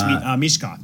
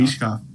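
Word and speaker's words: Michkov: Do you know the, that Russian kid, Mikhov, or yep. – I hope I'm saying his name right. – I think it's Mishkov, Matvey Mishkov. – Oh, Mishkov.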